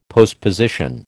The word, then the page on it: postposition: American English